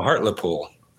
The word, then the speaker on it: Hartlepool: Help us out with the pronunciation. Hartlepool, I guess. – No, Hartlepool. Hartlepool. Hartlepool. Yes. Hartlepool.